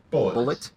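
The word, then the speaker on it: Bulleit: And for those interested, the bourbon that did this to me was called, it's spelled B-U-L-L-E-I-T. I want to say it's pronounced Blay. – Bullet. – Yeah. Okay. It's the French part of me wants to say boulet, but I wasn't trying to pronounce that.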